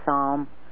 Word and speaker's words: Psalm: And folks, when Alan says Sam, we pronounce it Psalm.